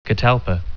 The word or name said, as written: catalpa